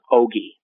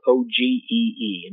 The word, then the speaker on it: ogee: In the palm of the hand, they saw a slit in the sky, which they called an ogee. That is spelled O G E E, an ogee.